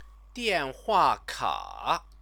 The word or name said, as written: dianhuaka